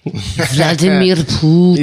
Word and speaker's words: Vladimir Putin: Vladimir Putin.